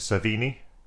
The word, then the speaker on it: Cervini: I think, like, my, the thing that I noted was the fact, I don't quite know how you say his name, Zach, is it Cervini? – Right.